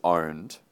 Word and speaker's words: pwned: I said, first of all, it's owned, okay? You don't pronounce the P.